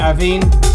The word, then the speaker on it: Abhean: If it was Irish, I'd pronounce it Aveen. A-B-H-E-A-N.